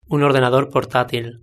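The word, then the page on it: un ordenador portatil: European Spanish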